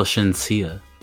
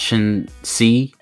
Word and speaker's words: Shenseea: How do you spell Shinsia? – S-H-E-N-S-E-E-A. – Are you sure it's pronounced Shinsia? – Yep. – It's not Shin C. – Nope, it's not that.